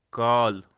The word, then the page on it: kaal: kashmiri